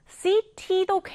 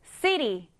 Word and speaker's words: city: 자, 강세는 앞에 넣어주시면 됩니다. 그래서 우리가 이 단어를 발음할 때 city도 괜찮지만 강세가 앞에 들어가다 보니 뒷부분을 약하게 city, city 이렇게 발음하는 경우도 많은 거예요.